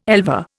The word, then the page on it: elver: Indian